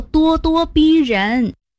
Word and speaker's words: 咄咄逼人: 哦，原来读作咄咄逼人。